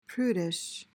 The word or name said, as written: prudish